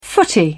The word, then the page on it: footy: British English